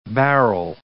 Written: barrel

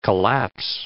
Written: collapse

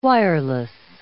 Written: wireless

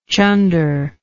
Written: chunder

